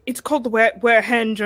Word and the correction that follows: Werehenge: I'm sorry, I said it wrong. I'm so nervous. It's called Werehenge.